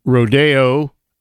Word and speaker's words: Rodeo: If you go, first of all, to Rodeo, that's the way Copeland pronounced it in his musical composition, Rodeo.